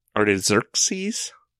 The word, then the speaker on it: Artaxerxes: The king that he's talking about is King Artaxerxes.